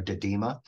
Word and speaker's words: didyma: So, Stephanie, if you go out to the eastern United States, let's stay upstate New York, there's another Monarda species called Monarda didyma. That is the one that most of the, and I think it's spelled D-I-D-Y-M-A. It's a funny one to pronounce, but didyma is also called Oswego tea.